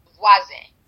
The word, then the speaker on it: Vwazen: And then can you tell, I'm definitely going to say this wrong, so I'll spell it, V-W-A-Z-E-N. – Voisin.